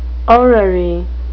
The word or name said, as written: orrery